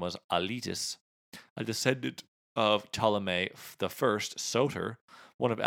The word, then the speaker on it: Ptolemy: She was the daughter of Ptolemy, which is how you pronounce it, but there's a P in front of it.